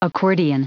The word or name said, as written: accordion